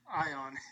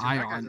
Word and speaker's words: Ion: Ion is how you say his first name. Ion.